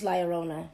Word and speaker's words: La Llorona: It's La Llorona.